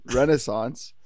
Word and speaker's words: Renaissance: I've been listening to many Australian podcasts. They pronounce it that way. They call that the Disney Renaissance. Damn, I almost did it again. Renaissance.